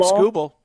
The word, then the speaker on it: Skubal: Am I saying his name right, Vern, for Detroit? Skubal? – You got it. Tarik Skubal. Yep.